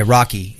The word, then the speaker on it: Rahkee: And there's a guy, his name is, he goes by Rocky. It's spelled really weird. R-A-H-K-E-E. Rocky. Yeah, kind of cool.